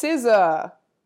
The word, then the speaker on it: SZA: It's SZA.